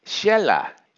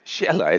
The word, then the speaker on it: Xela: Then there's another rather nice town in Guatemala, high in the Altiplano, called Xela. And you know what? There's not a single S in that word, Xela. It's X-E-L-A. How about this one? M-U-X-B-A-L, pronounced Mujbal.